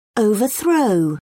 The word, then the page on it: overthrow: British English